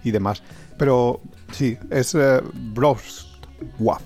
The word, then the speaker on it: Wrocław: Mira, desde Brovla, porque, bueno, la pronunciación es Wrocław, con una abierta.